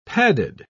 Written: padded

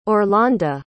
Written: orlanda

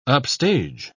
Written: upstage

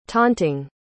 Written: taunting

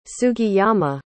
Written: sugiyama

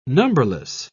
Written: numberless

